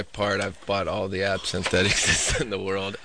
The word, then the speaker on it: absinthe: Yeah, it's absinthe.